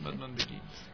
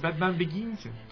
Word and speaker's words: Batman Begins: Batman Begins. – Yo soy mal en el inglés, chicos. Bueno, ya. – Dilo en francés. – No, peor. – Batman Begins.